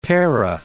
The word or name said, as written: Pera